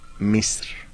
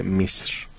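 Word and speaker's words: Misr (مصر): La palabra árabe de Egipto es Misr. Así significa, o se pronuncia en árabe, Misr.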